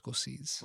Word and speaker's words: Scorsese: You want me to get you Martin Scorsese? – That's pronounced Scorsese.